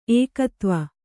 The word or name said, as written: ಏಕೋದೇವ